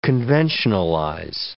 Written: conventionalize

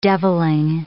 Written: devilling

